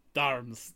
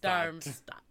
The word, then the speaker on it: Darmstadt: Darmstadt. – Darmstadt.